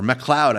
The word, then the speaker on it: MacLeod: I caught the documentary Royalty Free, The Music of Kevin MacLeod, or MacLeod, I think his name is actually pronounced,